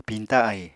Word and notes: piñta ahi: Apatani

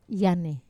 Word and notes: ane: Wamesa

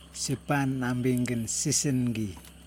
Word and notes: sipan nambiNgin sisiNgi: Yokoim

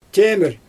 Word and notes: tʲemɪr: Xyzyl

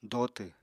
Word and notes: dotw: apatani

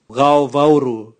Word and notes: gaovauru: Raga

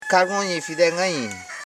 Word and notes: kagonifi: ka.go.ni.fi; Koro

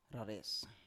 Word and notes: rares: Biak

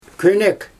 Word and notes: könek: Xyzyl

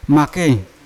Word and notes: make: Yawa

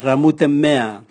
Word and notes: ramutemea: Raga

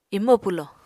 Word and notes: imo pwlo: Apatani

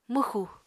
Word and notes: mwxu: Apatani

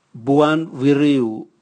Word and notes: buan vwiriu: Raga

